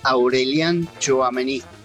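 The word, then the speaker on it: Aurélien Tchouaméni: Fer, yo te digo cómo se pronuncia, Aurelien Tchouaméni, jugadorazo.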